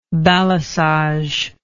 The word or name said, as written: balisage